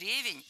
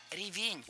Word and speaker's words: ревень: Конечно, это ревень, так сказал спикер. И смешивал он яблоки с ревнем. Давайте разбираться. Начальная форма не ревень, а ревень. Ударение в этом слове падает на второй слог.